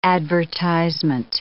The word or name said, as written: advertisement